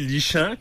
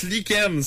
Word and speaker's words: lichen: Quelque chose qui se lit lichant, comme disait Marion. – Ben ouais, tu... – Pourquoi on le prononce lichen? C'est quoi le rapport? – Ça devrait être écrit comme L-I-K-E-N-N-E.